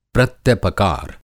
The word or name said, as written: प्रत्यपकार